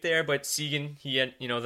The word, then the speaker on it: Seguin: That's how it's pronounced? – It is. It's Seguin. He's third in the NHL in scoring.